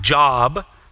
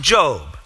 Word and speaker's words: Job: You might see his name and think it's pronounced Job, but we think it's pronounced Job.